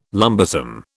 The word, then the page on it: lumbersome: British English